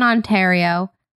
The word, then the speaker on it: Ontario: I live in Ontario. Is that how you say it? – Yeah.